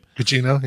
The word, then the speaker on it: Gugino: And by the way, Carla Gugini, or how you say her name? – Gugino, yeah.